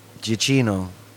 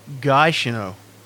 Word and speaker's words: Giacchino: It's Giacchino. – Or is it Giacchino? – No, it's Gia is Gia.